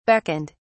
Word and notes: beckoned: British English

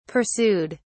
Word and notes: pursued: British English

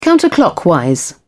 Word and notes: counterclockwise: British English

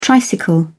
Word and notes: tricycle: British English

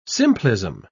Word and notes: simplism: British English

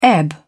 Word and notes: ebb: British English

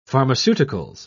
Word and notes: pharmaceuticals: British English

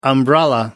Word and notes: umbrella: American English

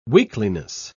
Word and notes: weakliness: British English

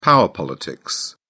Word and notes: power politics: British English

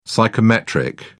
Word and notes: psychometric: British English

